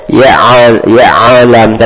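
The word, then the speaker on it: Ya'alam (يعلم): Jangan dikolok-kolok huruf ainnya. – Ya'alam.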